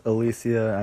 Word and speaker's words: Alicia: So, dating back to my third episode, when I was assessing the Baumgartner fight, I accidentally mispronounced her first name as Alicia. I know it's Alicia. I mean, I have a friend whose sister is named Alicia. I know how to pronounce that name.